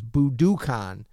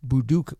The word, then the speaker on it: Budokan: So this is Budokan. Professor Hamamoto gave us the correct pronunciation, I'm assuming. Budokan. – Budokan.